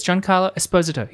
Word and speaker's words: Giancarlo Esposito: It's Giancarlo Esposito. He's Italian, not Spanish.